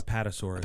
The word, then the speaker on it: Apatosaurus: A-P-A-T-O-S. – Apatosaurus.